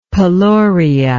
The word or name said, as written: peloria